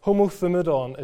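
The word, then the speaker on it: homothumadon (ὁμοθυμαδόν): The word in Greek which gives us that phrase is homothumadon, is how you might try to pronounce it.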